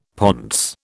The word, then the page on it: pontes: British English